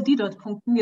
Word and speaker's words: Didot-Punkten: Die Entsprechung in 20 DTP-Punkten misst, ach so, Didot-Punkten. Jetzt weiß ich endlich mal, wie das ausgesprochen wird.